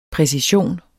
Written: præcision

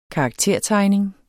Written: karaktertegning

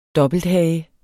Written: dobbelthage

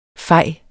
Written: fej

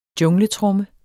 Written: jungletromme

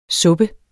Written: suppe

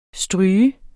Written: stryge

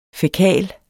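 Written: fækal

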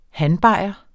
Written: hanbajer

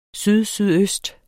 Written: sydsydøst

